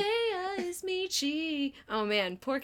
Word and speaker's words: Zendaya: We have to think of the song because they said it right in the song. It's Zendaya. It's Zendaya is Michi and LeBron James is Gwangi.